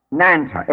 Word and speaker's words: Nanta: Nanta, N-A-N-T-A, for earth, that is pronounced N-R-N-T-A, Nanta, earth. N-R-N-T-A.